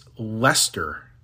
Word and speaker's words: Leicester: How do you pronounce that? It's Leicester. L E I C E S T E R. Leicester. There's an ice ICE in there for no reason